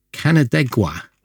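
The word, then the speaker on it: Canandaigua: Now, I found out how to pronounce this word by looking it up on the internet. Canadegua, I think, is how you pronounce it, which is not somewhere I've ever heard of before, but it was clearly on the tour.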